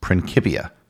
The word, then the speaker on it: Principia: Second, the proper pronunciation for the Principia Mathematica is Principia. This is because the Principia Mathematica is a Latin text. The whole book was written in Latin. In Latin, the letter C is always pronounced as a hard C, like the letter K.